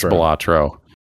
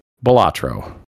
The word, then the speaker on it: Balatro: I think it's Balatro, I believe. That's how I've heard it pronounced. That's how I'm pronouncing it. Balatro.